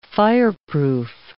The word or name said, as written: fireproof